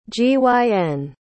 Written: gyn

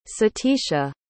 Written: satisha

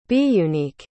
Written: biunique

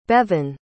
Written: Bevyn

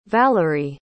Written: valeriy

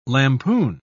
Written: lampoon

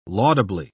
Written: laudably

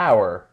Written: our

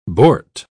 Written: bort

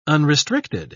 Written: unrestricted

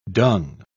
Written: dung

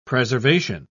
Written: preservation

